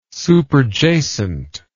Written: superjacent

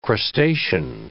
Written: crustacean

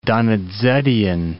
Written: Donizettian

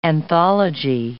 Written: anthology

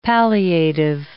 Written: palliative

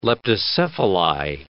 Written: leptocephali

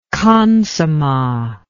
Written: khansamah

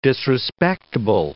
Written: disrespectable

